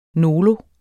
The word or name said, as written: nolo